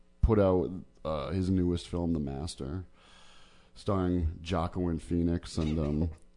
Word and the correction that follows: Joaquin: why you say it like that? – Philip Seymour Hoffman. I thought that's what it was. Jocelyn? – Did you? – What is it? – Joaquin.